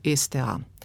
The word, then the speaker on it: STA: In zdaj, če pogledamo, prej sem omenjala slovensko tiskovno agencijo, STA, in sem izgovorila torej S z samoglasnikom prej in do zdaj je veljalo, da v tem primeru zapišem S, STA, izgovorim pa Z, STA. Ker pa se ta govorjeni jezik prenaša tudi v zapis, sedaj razmišljajo, da bi tukaj dopustili dvojnico.